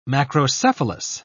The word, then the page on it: macrocephalous: British English